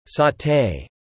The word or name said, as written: Saute